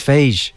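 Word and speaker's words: Fage: I think it's phage, like sage.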